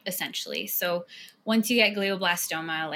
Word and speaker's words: glioblastoma: 25 years old, and you have been diagnosed with, and let's see if I can pronounce this right, glioblastoma. – Yes. Good job. That's right.